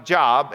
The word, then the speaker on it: Job: Now, it's spelled like Job, and it's pronounced Job.